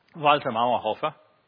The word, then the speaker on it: Walter Mauerhofer: Let me say it like a good German. Walter Mauerhofer.